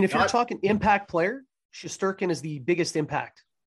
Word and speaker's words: Shesterkin: But they're, you lose to, I don't even want to, Shesterkin? Is that how you say his name? – Shesterkin, yeah.